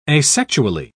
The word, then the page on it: asexually: British English